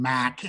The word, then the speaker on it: Mac: So you can find me pretty much anywhere, Mac McIntosh. But McIntosh is spelled M-C-I-N-T-O-S-H, no A, even though my first nickname, Mac, M-A-C, has an A, confuses people.